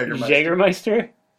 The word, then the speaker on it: Jägermeister: Yeah, Jägermeister.